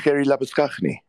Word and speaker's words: Labuschagne: Gerrie Labuschagne.